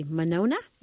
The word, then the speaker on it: Monona: We've got Rebecca calling from, I don't recognize the city, Monona? Am I saying that right? – That's correct.